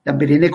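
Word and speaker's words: Lambaréné: Este, él eh, hizo este hospital en Gabón, lo que era África Ecuatorial Francesa, en, un, en una aldea que se llama Lamberé, Lamberene, Lamberene con doble, con doble acento en, eh, las voces.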